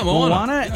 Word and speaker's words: Moana: Yeah, Moana.